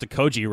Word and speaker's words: Okogie: Well, first of all, you pronounced Okoji right. We've been saying Okoji for 5 months.